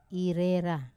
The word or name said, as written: rera